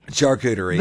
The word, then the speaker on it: charcuterie: Even though it's spelt with a C, you pronounce it with an S. Charcuterie.